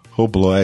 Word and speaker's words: Hublot: Hublot, é, desculpa, pronúncia. – Hublot.